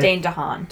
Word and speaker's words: DeHaan: Is it Dane DeHaan? Is that how you say it? – Dane DeHaan. – DeHaan. Okay.